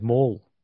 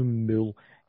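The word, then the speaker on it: Moule: Well, mine's Mole. So it's M-O-U-L-E. It's no Mule.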